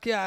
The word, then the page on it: kia: Ansus